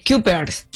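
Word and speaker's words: Cuypers: Es C-U-Y. – Kuipers.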